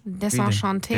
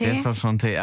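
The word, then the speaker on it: Désenchantée: You'll Be In My Heart von Phil Collins, Simple Life von Leonie und jetzt als erstes Kate Ryan mit Desenchanté, wird das ausgesprochen? – Desenchanté. – Desenchanté, ach, es ist französisch.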